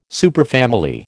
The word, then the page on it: superfamily: American English